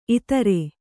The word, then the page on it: ಇತ್ತೆರ: kannada